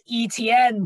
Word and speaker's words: Etienne: The Jaguars took Travis Etienne, running back from Clemson. – Oh, please. It's pronounced Etienne, bro. – I don't care. I've pronounced it Etienne like the entire time. – It's freaking like guillotine or something like that.